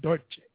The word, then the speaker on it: Dolce: She'd be proud of me that I said that right, Dolce.